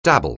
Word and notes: dabble: anglais

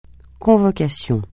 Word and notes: convocation: francais

